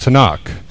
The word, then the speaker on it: Tanakh: And it's pronounced Tanakh.